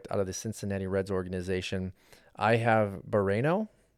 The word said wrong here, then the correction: Barrero: I think it's pronounced Barrero. – Yes, so like burrito.